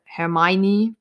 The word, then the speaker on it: Hermione: oder Hermione oder Hermione, wie das ja auf Englisch ausgesprochen wird.